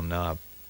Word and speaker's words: knob: How do you spell knob? With a K? Or with just an N? – With a K. N-O-B-K. It's a silent K.